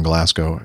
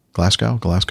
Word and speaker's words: Glasgow: I live not a million miles away from Glasgow Airport. – Glasgow. – I always say that wrong.